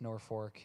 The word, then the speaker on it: Norfolk: If you're from there, it's pronounced Norfolk.